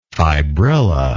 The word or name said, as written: fibrilla